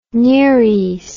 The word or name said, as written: nereis